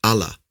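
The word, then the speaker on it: Allah (אלה): And what's interesting there, again, at least to me, is that the word curse is Aleph Lamed He, pronounced Allah, which also is the word that's used in Daniel chapter 9, verse 11, to speak of the curse, Ha'Allah, of the Torah.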